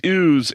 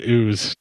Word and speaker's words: ewes: You know it's pronounced ewes, right? – That's what I just said. Ewes. – It sounded like you said ooze. – No, I said ewes.